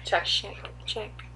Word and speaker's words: Czech: and how do you say that? The Czech Republic? Is that how you pronounce it? – Yeah, Czech. Yeah. That was my latest search.